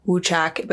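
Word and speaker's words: wuchak: And it's pronounced like woochack, but it sounded so similar to woodchuck that they started to call it woodchuck.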